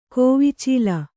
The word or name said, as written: ಕೋವಿಚೀಲ